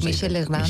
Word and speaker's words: Michel Legrand: Que son canciones de Cole Porter, Jeremy Kerr, Michael Legrand, o Michel Legrand, no sé cómo se dice. – Sí, Michel Legrand.